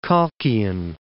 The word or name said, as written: Colchian